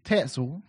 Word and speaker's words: Tetzel: So we had a guy in the town next door to Wittenberg, where Luther lived, a guy named Tetzel, whose name rhymes with pretzel.